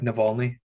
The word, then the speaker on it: Navalny: Mine was the documentary Navalny. That's what it is, right? Navalny? – Yeah.